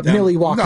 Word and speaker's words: Milwaukee: I know you were hot on the Dodgers win last night in Milwaukee, or as we pronounce it, Milwaukee.